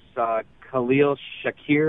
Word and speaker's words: Khalil Shakir: Khalil Shakir, am I saying that right? – Yep, you got it.